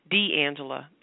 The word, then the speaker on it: D'Angela: D'Angela.